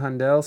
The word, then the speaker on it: Handel: Handel, is that how you say it? Handel scored our lives as well.